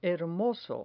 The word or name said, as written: hermoso